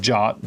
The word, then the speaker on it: JWT: So if I just went to a search engine and typed in JWT decode, it's pronounced JOT, JWT stands for JSON web token, so JSON web token.